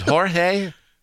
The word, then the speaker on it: Jorge: For those that don't know, two nights ago, O'Neill Cruz, after a bench-clearing incident involving pitcher Jorge Lopez. Is it Jorge? – Yeah, Jorge. Yeah.